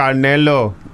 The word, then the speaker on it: Canelo: So Canelo. You have to say it like that.